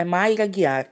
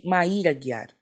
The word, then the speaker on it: Mayra Aguiar: No nosso último episódio sobre as Olimpíadas, eu errei a pronúncia do nome de uma atleta. O nome da atleta é Maíra Guiar e eu pronunciei Maíra Guiar.